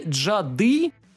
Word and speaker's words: джады: и «джады», ударение на последний слог, «ведьма».